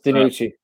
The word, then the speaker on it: DiNucci: And then DiNucci, obviously, I don't even know if I'm pronouncing that name right. – It's DiNucci.